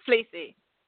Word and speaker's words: Fleassy: Fleassy. Fleassy, yeah. – Like a jumper. – Fleassy. – Yeah. – Fleassy, like a jumper.